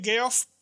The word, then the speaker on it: Geoff: Geoff, se escribe Geoff, pero en la convención descubrimos que se pronuncia Geoff, nace en Detroit en 1973.